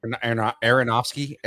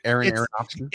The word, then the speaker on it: Aronofsky: I'm going to butcher his name. Aronsky's protozoa. – Aronofsky. Aronofsky.